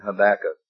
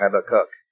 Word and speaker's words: Habakkuk: Nahum, Joel, Zephaniah, and Habakkuk, or Habakkuk, as we sang in the song. Either way, pronunciation there.